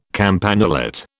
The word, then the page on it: campanulate: British English